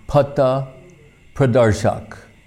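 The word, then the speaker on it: Patha Pradarshak: one of which is known as Patta Pradarshak. Pat means like path, like the English word path. It's spelled similarly, but in Sanskrit, pat, patta. So Patta Pradarshak, just like the word darshan, Pradarshak, so a Patta Pradarshak means way shower.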